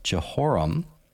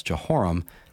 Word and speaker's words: Jehoram: His son Jehoram, three syllables, Jehoram succeeded him.